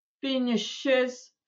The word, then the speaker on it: finishes: Поэтому всовываем туда небольшую гласную и finishes, чтобы просто звучало как-то лучше.